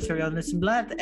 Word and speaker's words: Nissenblatt: I am at Ari this and that, A-R-I this and that, because my last name is Nissenblatt and it rhymes. – So thank you so much, Arielle Nissenblatt.